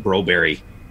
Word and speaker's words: Broberg: Funny thing is, actually, some of the announcers down there, as well as Bruce McCurdy, calls him Broberry, because that's actually how his name is pronounced in Sweden.